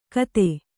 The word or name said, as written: ಖತ